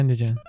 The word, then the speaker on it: Andijan: Is that how you pronounce Andijan? – Yeah, Andijan.